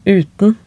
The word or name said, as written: uten